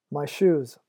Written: my shoes

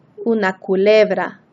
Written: una culebra

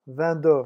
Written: vingt deux

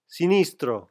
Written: sinistro

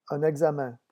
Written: un examen

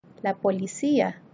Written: la policia

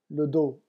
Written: le dos

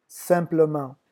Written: simplement

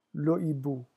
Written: le hibou

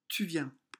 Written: Tu viens

